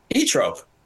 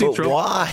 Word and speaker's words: Zoetrope: Everybody knows it's E-trope. – But why and how? – Oh, I don't know why. I don't know why. I don't know the etymology of that word and the pronunciation of it, but I just know it's E-trope. – I mean, Francis Ford Coppola's film company is not called American E-trope. It's called American Zoetrope.